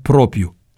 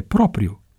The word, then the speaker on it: propriu: Aceasta mi este declarația pe care o dau și o semnez în nume propriu. Corect este propriu, nu propriu.